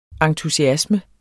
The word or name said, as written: ENTUSIASMEN